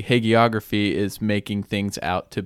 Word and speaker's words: hagiography: So this is kind of getting back to what you mentioned, hagiography, right? Did I say that right? – Yeah.